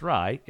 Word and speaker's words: Ray: how to pronounce Rye's name. Because the host of that show points out that she has apparently just learned that Rye is pronounced Rye in Bengali, whereas she knows it in Hindi as Ray.